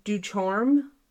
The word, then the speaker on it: Ducharme: In 2012, Michael Ducharme pled guilty. – Ducharme. That's my dad's best friend's last name. That's the only reason I'm correcting you. Okay. I'm Ducharme. Pretty sure that's how it's said. – Yeah, it's D-U-C-H-A-R-M-E.